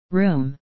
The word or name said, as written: room